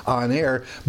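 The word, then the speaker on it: Aner (ἀνήρ): It's not just a anthropos, but he's an aner. Almost sounds like honor, doesn't it? Aner. It's A-N-E-R, long E pronounced A, on air, but it means kind of a cut above, just plain old generic man.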